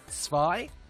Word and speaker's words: Zwei: Veritex in the chat room says that in German, Z is pronounced T-S. I presume like... So, Zwei. Is that Zwei?